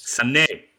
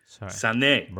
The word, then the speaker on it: Sané: Sané. Remember, there's the accent on the E. Sané.